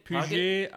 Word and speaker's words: Puget: I'm not sure how to say this, you're the French expert. Puget? P-U-G-E-T? – Puget? Puget? I believe in America that would be Puget.